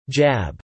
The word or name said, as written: jab